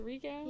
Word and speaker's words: Rico: Is it Rico? – Yeah. Rico. – Rico. I can't do that. – He told us that's how we're supposed to pronounce it. – Yeah, he did. – With the rolling R.